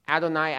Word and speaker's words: Adonai: That is because the Hebrews would have pronounced it Adonai, Adonai, because the Hebrews did not want to pronounce God's proper name of Yahweh because it was so honored.